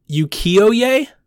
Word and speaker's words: ukiyo-e: it's a Japanese art style called ukiyo-e.